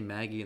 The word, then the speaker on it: Magi: I think a better character to compare him to is Sinbad from Magi, or Maggie, because they say Maggie in the show, but I pronounce it Magi.